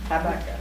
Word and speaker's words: Habakkuk: It's spelled Habakkuk, but it's Habakkuk, I suppose.